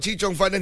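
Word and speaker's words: chee cheong fun: I mistyped and mispronounced the name. Uh-huh. And they said, bro, it's called chichong fan.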